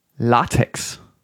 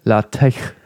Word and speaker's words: LaTeX: La Latex ist es eigentlich. Dieses Latex ist, ist ja nur so die deutsche Fassung. Eigentlich soll es ja der Ach-Laut sein, gell? Ist nur im Deutschen wird es LaTeX ausgesprochen.